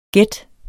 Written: gæt

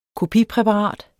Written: kopipræparat

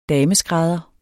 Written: dameskrædder

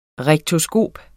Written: rektoskop

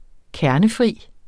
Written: kernefri

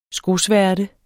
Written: skosværte

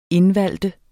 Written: INDVALGTE

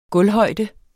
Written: gulvhøjde

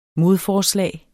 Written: modforslag

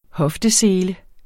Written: HOFTESELE

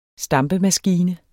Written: stampemaskine

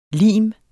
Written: lime

